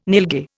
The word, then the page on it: nilgai: Indian